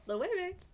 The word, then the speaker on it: Loewe: But like, it should be that though. Loewe, it doesn't look like that, does it? – There's no B. It doesn't make any sense. It doesn't look. – No, it's literally something that someone decided that's how it's said. And then only if you hear it, you'll know how it's pronounced.